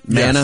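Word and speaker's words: Maná: Billy Idol, Mana. Did I say that right? Mana? – Yes.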